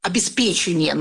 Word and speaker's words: обеспечение: Например, мы говорим, что обеспечение нужно говорить чаще всего.